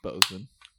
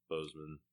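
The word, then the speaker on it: Boseman: All right, well, we're starting this off pretty sad, honestly, but we're buying around for Chadwick Bossman. Bozeman. Bozeman. I always say it wrong.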